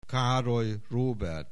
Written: Károly Róbert

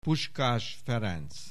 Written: Puskás Ferenc

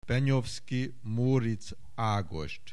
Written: Benyovszky, Móric Ágost

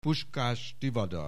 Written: Puskás Tivadar

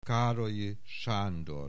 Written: Károlyi Sándor

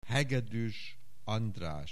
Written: Hegedues Andras

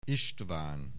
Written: István

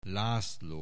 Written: László

